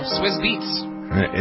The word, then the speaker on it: Swizz Beatz: Swizz Beatz? Is that how you pronounce it? Am I right? – Way to go. Swizz Beatz.